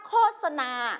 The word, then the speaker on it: โฆษณา: โฆษณาเราไม่ออกเสียงว่าโฆษณานะเราออกเสียงว่าโฆษณา